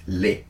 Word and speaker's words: les: So when you say, for example, los, the, like the article the, in French is les, L-E-S, right? But we, how do we pronounce it?